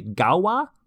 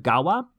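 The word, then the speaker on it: Gaua: And this is around where they first contacted people on the island of, I'm going to mispronounce this, it's G-A-U-A, Gawa, I think, the island of Gawa.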